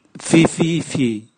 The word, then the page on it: vivivii: Raga